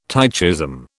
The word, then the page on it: tychism: British English